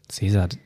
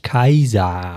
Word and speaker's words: Caesar: Caesar, den hat man gar nicht so ausgesprochen, der ist eigentlich Kaiser.